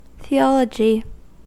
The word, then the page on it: theology: American English